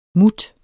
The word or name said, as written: mut